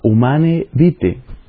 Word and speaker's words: Humanae Vitae: Pablo VI hace un análisis muy interesante en un documento publicado en 1968 que se llama Humane Vitae. En latín se escribe Humanae, para los que lo vayan a buscar quizás en internet humanae vitae, se pronuncia humane vitae.